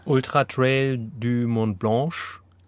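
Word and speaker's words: Ultra-Trail du Mont-Blanc: Ultra Trail du Mont Blanche.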